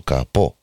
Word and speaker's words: Capó: El Pedro Capó, con acento en la O, el eh, Capó del coche.